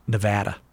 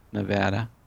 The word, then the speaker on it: Nevada: People from Nevada. – Nevada. – They don't like it if you say Nevada. – Oh, my. – It's tomato, tomato. – Nevada.